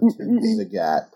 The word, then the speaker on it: Zagat: Yeah, which is how Zagat pronounces his name, right? – Correct. Yeah. We often tell people it's Zagat like the cat. Rhymes with cat.